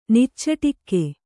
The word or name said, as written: ನಿಚ್ಚಟಿಕೆ